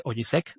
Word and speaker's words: Osijek: Osijek de Croacia.